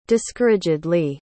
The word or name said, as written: discouragedly